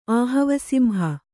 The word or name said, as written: ಆಹವಸಿಂಹ